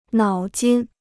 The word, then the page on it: 脑筋: nǎo jīn